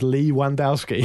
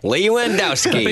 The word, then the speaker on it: Lewandowski: Phil Pearce also says my highlight was a friend of mine actually pronouncing Lewandowski's name as Lee Wandowski. – Nice. – Lewandowski.